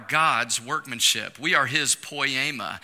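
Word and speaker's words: poiema (ποίημα): It's pronounced poiema.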